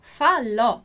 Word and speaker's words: falò: Então, a sílaba tônica aqui, a sílaba forte, é ló, falò.